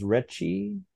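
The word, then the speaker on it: Rechy: Now the writer, his name pronounced Retchie, John Ritchie, yes.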